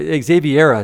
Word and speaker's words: Xaviera: That's what Xaviera is how you pronounce it. But I always call it Xavier. And your mom said, that's a guy's name. And I said, oh, I've been pronouncing it wrong since I was a teenager.